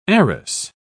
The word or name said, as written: arras